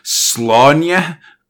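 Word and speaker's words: Sláine: Technically, the way you pronounce it is, like, Slaunya, but I'm not going to do that. Go to hell with that. His name's Slane.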